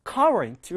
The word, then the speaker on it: current: 자, 앞에 강세가 있어서요. current 이렇게 발음하시면 됩니다.